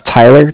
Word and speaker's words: Tyler: My name is Tyler, T-Y-L-E-R. – Uh-huh. – And I'm calling from Bank of Cardiff. It's spelled C-A-R-D-I-F-F, as in Frank Frank.